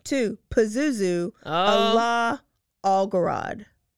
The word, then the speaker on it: Pazuzu: Pazuzu.